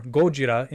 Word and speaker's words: Gojira: So, you take the Japanese pronunciation of gorilla, which would be Gorira, and... Gordita? And not Gordita. Gordita. And the Japanese word for whale, Kujira. So you have Gojira.